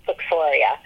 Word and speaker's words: Phylloxera: And it's spelled Phylloxera because nobody, I don't say it right, but it's Phylloxera.